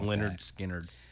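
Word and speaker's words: Lynyrd Skynyrd: Can't it's pronounced Leonard Skinner. – It's pronounced, yeah. It's pronounced what they say.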